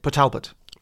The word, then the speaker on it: Port Talbot: That's how you say it. Port Talbot.